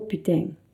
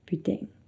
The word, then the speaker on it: putain: Si vous allez dans le sud de la France, putain sera prononcé différemment. On dirait plutôt putain, putain. Donc par exemple à Paris ou dans le nord de la France, on dira putain, mais dans le sud de la France, on dira putain. Donc la prononciation est assez différente.